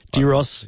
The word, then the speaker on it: Gyros: You come home at 5 in the morning and you have one of those wraps on the way home that you'll say it right. Gyros.